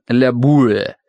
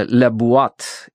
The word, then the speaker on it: La Boîte: Папа в шутку называет магазин «La Boîte», но на самом деле это произносится «La Boîte» и означает «коробка».